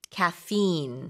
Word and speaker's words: caffeine: 이것도 영어 발음 좀 다른데 우리는 카페인, 영어로는 caffeine. – Exactly.